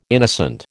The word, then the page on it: innocent: American English